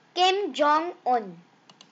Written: Kim Jong-Un

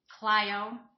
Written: Clio Michigan

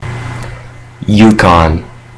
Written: Yukon

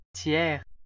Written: Thiers

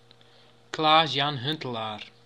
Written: Klaas-Jan Huntelaar

